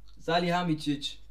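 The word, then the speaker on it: Salihamidžić: Salihamidzic. – Irgendwie Salihamidzic. – Ja! Salihamidzic.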